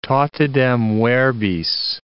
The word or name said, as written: totidem verbis